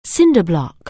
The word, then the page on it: cinder block: anglais